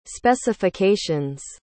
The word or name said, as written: specifications